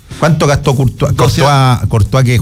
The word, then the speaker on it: Courtois: A Thibaut Courtois.